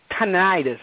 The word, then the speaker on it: tinnitus: tinnitus. I'm sorry, I'm not saying it correctly. – Yeah. No, it's tinnitus, but in Australia, the way they said it in the film, it sounds really different. But here in the States, we normally call it tinnitus.